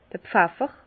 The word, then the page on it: d'r Pfeffer: Bas Rhin; Herrlisheim